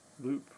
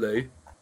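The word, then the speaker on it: Loup: Yes, Steve and Loop, right? – Yeah, Steve and Liu. Because French, so we don't pronounce the P, although I always say Loop rather than Lou.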